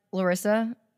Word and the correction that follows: Lyrsa: Wait, Lysa? How do you say her? Lyrsa. – Lyrsa.